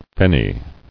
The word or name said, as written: Fenny